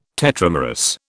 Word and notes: tetramerous: British English

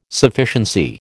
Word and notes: Sufficiency: American English

